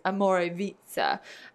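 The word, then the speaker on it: Omorovicza: Everyone says it wrong. So it's spelled Omorvicza. And it's Amorevitsa.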